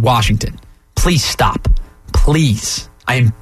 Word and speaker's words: Washington: There is no R in the word Washington.